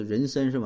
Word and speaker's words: 妊娠: 啊，是这个呃人参纹哈，啊就是妊娠这俩字一般读妊娠哈，就标准读音是人参是吧？